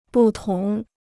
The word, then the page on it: 不同: bù tóng